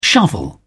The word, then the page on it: shovel: British English